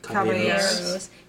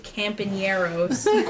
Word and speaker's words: Caballeros: Caballeros. – He says the three campaneros. And I'm like, Michael, that's not how you say it.